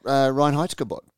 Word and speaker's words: Reinheitsgebot: Ryan Heitzgerbot, the German... did I pronounce that correctly, Pete? – Yeah. – Correct. – I've definitely been pronouncing that wrong.